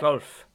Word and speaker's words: Volf: And I don't know if I'm going to say this right, Miroslav Volf. Is that right? – Wolf. – Yeah. – Miroslav Wolf. – There you are.